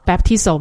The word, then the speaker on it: baptizo: 新约圣经里面呢，讲到这个中文翻成洗礼的这个词啊，在希腊文的原文里面的话呢，的确照着那个字面的意思来讲的话 baptizo 嘛，这是它原来希腊文的原文的发音，是进入或沉入水中的意思。